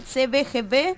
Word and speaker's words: CBGB: Y los van a estar tocando el próximo viernes, 1 de julio, en el CBGB, se pronuncia, en el CBGB, claro, en la calle Bartolomé Mitre al 1551.